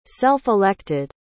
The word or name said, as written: self-elected